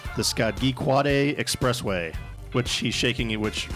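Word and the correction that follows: Scajaquada: Rick is saying, shaking his head, so I don't think I pronounced it correctly. – Skajakwada. – Skajakwada. – There you go.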